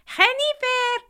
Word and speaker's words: Jennifer: But there was one exception, my mother-in-law, who called me Jennifer. Jennifer! Or Hen. But her first language was Spanish, and the American J doesn't exist in Spanish, so she pronounced it phonetically in Spanish.